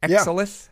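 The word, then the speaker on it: Exalith: Did I say that right? – Exolith? – Yeah. I think so.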